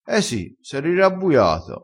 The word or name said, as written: rirabbuia